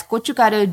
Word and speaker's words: gochugaru: It's gochugaru, G. G-O-C-H-U, G-A-R-U, gochugaru.